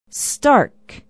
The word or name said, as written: stark